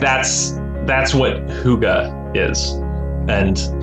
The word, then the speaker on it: hygge: It's hygge. It's a Dutch word. Yeah, it doesn't look like it's it's H-Y-G-G-E.